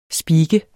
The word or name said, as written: speaker